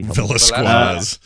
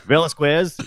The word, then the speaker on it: Velasquez: How do you say that last name? – Velasquez. – Velasquez. Yeah, squeeze. Velasquez.